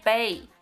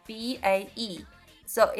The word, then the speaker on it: bae: 啊、就是说以前经常会指美女呀、或者是辣妹呀、但是跟中国很像。我们现在也是，只要是女的，我们就说哎嗨美女，或者是只是变成了一个称呼。但是我还听到一种方式，它会读成 bae b a e。So is it a slang？ – Yes, it is a slang.